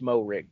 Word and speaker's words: Moehrig: Trevon Moehrig. – I'd assume the H is silent and it is Moehrig, yes.